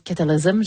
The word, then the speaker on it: Cataclysm: Je le disais à la française, ça doit être Cataclysm, j'imagine. Oui, c'est ça, je l'ai bien prononcé. Cataclysm, en tout cas, mon anglais est rouillé.